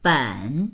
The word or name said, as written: ben3